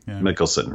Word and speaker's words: Mikkelsen: The like, what's his name? I always say it wrong. – Mikkelsen, right?